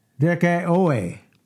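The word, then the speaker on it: dikaioō: And that's spelled D-I-K-A-I-O-M-A. Okay? Dikaiome. And it's from the Greek word dikeuo. Dikeuo. That's spelled D-I-K-A-I-O. The first O is short. Okay? And the next O is long. Dikeoe.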